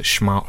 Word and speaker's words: Smaug: I don't believe there is for that particular pronunciation of Smaug, but I think the argument is it's based on the German, and I'm going to say this wrong, Smaugen or something.